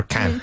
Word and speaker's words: can't: And I say, did you say can or can't? So very important you pronounce the T.